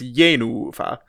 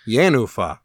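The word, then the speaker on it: Jenůfa: To check out Janacek's Janufa, at Lyric Opera of Chicago. – I think it's an accented first syllable and a long unaccented second syllable, so it's Janufa. – Janufa.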